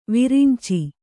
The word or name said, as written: ವೈರಿಂಚಿ